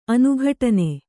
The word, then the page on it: ಅನುಘಟನೆ: anughaṭane